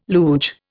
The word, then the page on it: luge: Indian